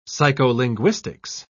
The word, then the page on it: psycholinguistics: British English